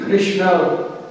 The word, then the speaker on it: Krishna: Krishna is the correct pronunciation and Krishna is a wrong pronunciation. That's a long A in the wrong pronunciation. When you see the Krishna, there is no dash at the top of the A. But Ra is a long. So it's changing the meaning.